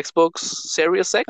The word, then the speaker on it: Xbox Series X: la nueva consola se llama Xbox Series X.